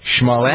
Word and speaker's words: Smollett: I know, did I pronounce Smollett? – Smollett. – There's no sh in it.